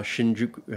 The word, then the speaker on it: Shinjuku: My first day, I was bombarded by uh, by the main uh, square, uh, Shinjuku.